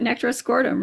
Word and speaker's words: Nectaroscordum: Nectaroscordum, right.